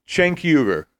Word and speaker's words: Cenk Uygur: Cenk Uygur.